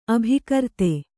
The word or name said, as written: ಅಭಿಕರ್ತೆ